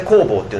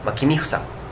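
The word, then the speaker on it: 公房: きっていうか憧れかなっていうふうに思うんですけど、安倍工房のそのなんだろう、僕が安倍工房に持つ憧れっていうのは、多分彼の作品、もっと言えば作品を作る過程で、彼自身が作家になる前の安倍工房っていうのはまあペンネームなんですけど、本名は君房、公園の公に房、それを読み方が違うだけで、音読みで工房っていうのをまあペンネームにしてるんですけども、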